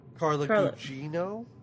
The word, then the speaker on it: Gugino: Carla Gugino.